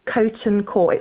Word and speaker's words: Coton Court: It's called Coton Court. It's spelt like Cough. – Okay. – T-O-N. – Yeah. Okay. Cough. T-O-N. Court. Got it.